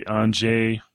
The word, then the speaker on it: Angier: I thought it was really interesting narrative to have read the Borden diary and then to read the Anjay. Is that? Yeah. It's pronounced, you know, it's spelled differently than it's pronounced,